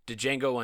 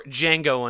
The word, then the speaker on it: Django: Also, Machete Kills, Django Unchained, or Django Unchained, and The D is Silent, The Hateful Eight, and Ant-Man and the Wasp.